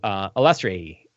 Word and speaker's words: Illustrei: It's like illustration, Illustrei.